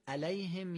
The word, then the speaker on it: عليهم: ما باید بگیم علیهم یا علیهم، دومی درسته. اگر معمولی مثل فارسی بگیم علیهم، این عین تبدیل به الف میشه.